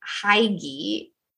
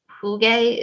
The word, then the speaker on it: hygge: I used to call it Hygge. But that's not apparently how it's pronounced. Oh. It's a closer, Hugge is more closely how it should be pronounced.